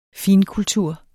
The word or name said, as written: finkultur